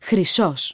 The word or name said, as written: χρυσός